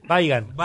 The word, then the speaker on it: Weigand: El único señor que le dijo, ¿cómo decía a Weigand? ¿Usted? – Weigand. – Weigand, como se pronuncia.